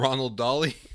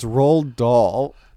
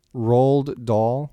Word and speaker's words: Roald Dahl: It's Roald Dahl. – I decided to add in more letters. – I like Ronald Dolly. – Ronald Dolly. Say it again. How's it? His name actually pronounced? – Roald Dahl.